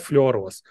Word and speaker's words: флюороз: Эм, по-русски, наверное, было правильно говорить фторос, но на английском фтор – это а, флюорин, поэтому говорят флюороз.